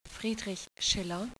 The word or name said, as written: schiller